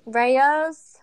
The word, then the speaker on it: Rao's: Rao's? – It's Raos.